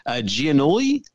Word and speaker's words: Giannulli: Giannulli? – Yeah, I believe that's correct.